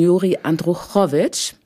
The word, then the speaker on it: Juri Andruchowitsch: von Juri Andruchowitsch, habe ich es jetzt richtig ausgesprochen? – Ja.